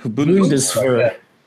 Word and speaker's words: Bundeswehr: Bundeswehr.